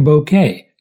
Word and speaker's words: Bocquet: Silo production designer, and I'm going to use the French pronunciation because it is a French Oregon name. Gavin Bouquet talked to me about designing the silo.